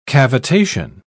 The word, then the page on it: cavitation: British English